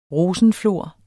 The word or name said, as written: rosenflor